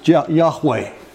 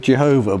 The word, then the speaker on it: Yahweh: We often pronounce it as Yahweh. We used to call it Jehovah, but that got the vowels wrong. Hebrew doesn't have vowels, so there's just four letters.